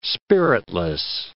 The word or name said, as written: spiritless